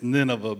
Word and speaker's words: Nineveh: I'm glad I'm not the only one that cannot pronounce the Bible names. It's Nineveh, by the way. You'll never forget Nineveh now.